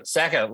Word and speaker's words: Saka: Saka, like Saka shit.